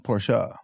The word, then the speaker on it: Porsche: Porsche. – Well, I say Porsche. What is it? – Porsche. It's how you pronounce it. – Porsche.